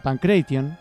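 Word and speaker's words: pankration: Pancracio, que en el griego se pronuncia, bueno, en latín se pronuncia Pancration, que significa todos poderes o todas habilidades.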